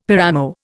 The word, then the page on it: paramo: Indian